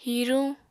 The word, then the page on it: hiruñ: Apatani